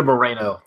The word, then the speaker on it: Moreno: Rita Moreau as Louise, Carol Kane as Jennifer. – I'm sorry, it's Rita Moreno. That's one name you do not get to pronounce in this house.